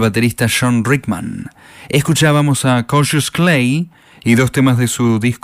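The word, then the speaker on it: Cautious Clay: Suena como Cassius Clay, aunque se escribe cautious.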